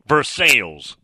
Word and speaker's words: Versailles: I was told firsthand that they call Versailles Versailles. That is true. I'll give you one. Muletown, Tennessee, where Kevin resides, is M-A-U-R-Y County, which you would think that would be Maury or something, right?